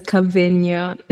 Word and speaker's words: Kavinya: My name is Kavinya.